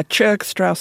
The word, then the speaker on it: Churg-Strauss: Yeah, Churg-Strauss syndrome.